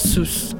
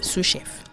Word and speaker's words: sous: Not sous. – No. The S is silent. Sous chef. – Okay, because it's French.